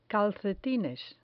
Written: calcetines

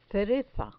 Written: cereza

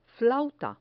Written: flauta

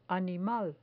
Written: animal

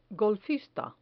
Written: golfista

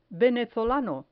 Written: venezolano

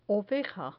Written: oveja

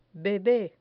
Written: bebe

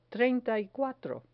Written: treinta y cuatro

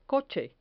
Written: coche

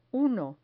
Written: uno

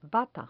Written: bata

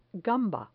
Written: gamba

